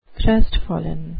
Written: crestfallen

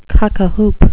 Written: cock-a-hoop

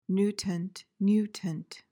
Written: nutant

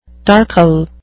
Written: darkle

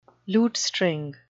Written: lutestring

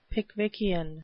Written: Pickwickian

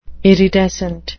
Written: iridescent

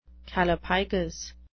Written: callipygous

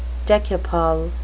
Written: decuple

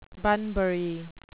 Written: bunbury

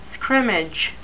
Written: scrimmage

